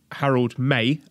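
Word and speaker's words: Harold Meij: The soon to be new president of New Japan, Harold May, and that is the confirmed pronunciation. I asked my Dutch teammate on my football team and he says that's how it's pronounced.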